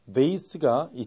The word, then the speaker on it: vase: 그 다음 VASE도 이 미국식인 vase가 있지만 영국식은 때에 따라서 vase 이렇게 많이 읽습니다.